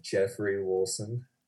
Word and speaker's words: Geoffrey Woolson: It's Geoffrey Woolson. – Geoffrey Woolson. – If you're going to say it with the American accent.